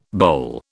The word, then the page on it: boll: British English